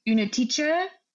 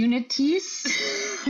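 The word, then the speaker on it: Únětice: I believe it's the Unetice. The Unetis. I can never say it correctly. I feel really bad every time I say it. It's a Czech word. So that's why I can't pronounce very well. But like Unetice, I think, is the closest I'll get.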